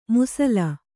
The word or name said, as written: ಮುಸಲ